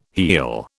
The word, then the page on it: heal: British English